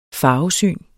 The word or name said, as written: farvesyn